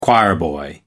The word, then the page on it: choirboy: American English